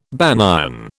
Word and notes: banyan: British English

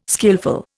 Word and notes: skilful: Indian

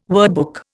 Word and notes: wordbook: Indian

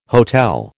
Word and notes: hotel: American English

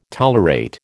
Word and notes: tolerate: American English

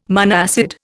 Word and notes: monoacid: Indian

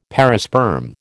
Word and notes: perisperm: American English

